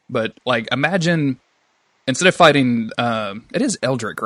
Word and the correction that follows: Aldrich: Or what is the guy's name? – Aldric. – Aldric. Thank you. Yeah, I knew I was saying that wrong. I just couldn't think of how. – You were getting Aldrich, and Eldritch and all.